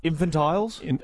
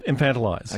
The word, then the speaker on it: infantilize: infantile. How do I say this, young man? I can't even repeat it. – Infantilize. – Yeah. – Infantiles? – Infantilize.